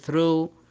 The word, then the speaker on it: through: دائما حرف الجي والاتش إذا أتى في وسط الكلمة يعني لم ينتهي بها يكتب ولا ينطق، هذا في الغالب. أما لو أتى في نهاية الكلمة في الغالب دائما ينطق بحرف الفاء، مثل كلمة enough أو كلمة calf. لكن في حالات شاذة مثل كلمة although أو كلمة through